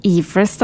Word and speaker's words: Everest: Det mye fjell i Nepal, og mye høye fjell, og Mount Everest, eller Everest, da, som jeg har lært uttales, ligger jo der.